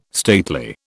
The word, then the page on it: stately: British English